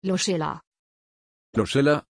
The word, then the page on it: Lucilla: German